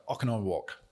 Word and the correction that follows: Oconomowoc: I love it. We have a lot of Native American history in the state of Wisconsin, so it's actually pronounced Oconomowoc. – Oconomowoc.